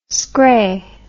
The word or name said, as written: scray